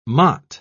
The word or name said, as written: motte